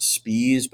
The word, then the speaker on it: Spies: And so I talk at some length in that chapter about the statement at the end of the trial given by August, it's probably pronounced Spies, but it's spelled Spies, S-P-I-E-S.